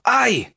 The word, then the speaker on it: I: Solo que en inglés se pronuncia como si nos pegáramos un quemón con la estufa. ¡Ay! ¡Ay! ¿Mm? Esa sería la manera de decir yo.